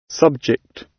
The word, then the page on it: subject: British English